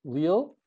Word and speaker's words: Lille: He's also now professor at the Catholic University of Lille.